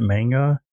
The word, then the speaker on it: manga: Manga? – Manga.